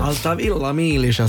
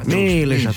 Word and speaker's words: Altavilla Milicia: Altavilla Milicia si dice. – Milicia. – Milicia, te lo dico proprio con l'accento.